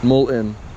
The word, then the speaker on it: mullein: Mullein.